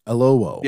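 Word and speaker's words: Oyelowo: This is a new show on Paramount Plus, and it stars, and I always get his last name wrong, David Iloho. Is that right? David Iloho? – I think you're right. Iloho.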